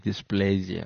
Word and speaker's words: dysplasia: dysplasia.